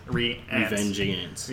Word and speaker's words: Revengeance: Okay, so we've been mispronouncing it, by the way, as well. It's Revengeance.